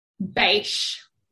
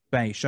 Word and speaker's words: Bache: Bache. – Bache.